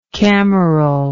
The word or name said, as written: cameral